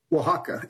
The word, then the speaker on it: Oaxaca: In this particular province, it's OAXACA. I don't think I've ever heard that said. How do you say that? – Oaxaca.